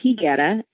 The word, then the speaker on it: Higuera: It's actually pronounced Higuera, and it means fig tree.